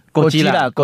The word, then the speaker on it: 哥吉拉: 对啊，提到这个两只怪兽呢，提到日本的怪兽，不免让人家不免想到最近最热门的话题就是嘎吉拉。哎，我我发音对吗？ – 哥吉拉，哥吉拉啊，哥吉拉。